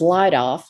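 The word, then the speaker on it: Leidolf: Well, you would search for Elaine, and it's E-L-A-I-N-E. And then my maiden name, which is Leidoff, let me spell it because it's an old German name. Mm-hmm. It's L-E-I-D-O-L-F, and Davis is my last name.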